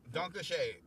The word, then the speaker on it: Danke schön: Danke schön.